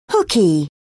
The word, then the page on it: hooky: British English